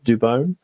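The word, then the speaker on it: Dubón: I believe the commentators in San Francisco are now finalizing that that is how you pronounce his surname, Dubon.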